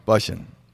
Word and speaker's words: Bussian: I'm Carl Bussian. – Bussian. – Yeah. – That's how it does rhyme with Russian.